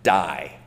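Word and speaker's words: dei: Incidentally, in English, it can be pronounced as die, okay? It's D-E-I, was how we would kind of transliterate it.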